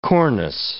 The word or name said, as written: cornice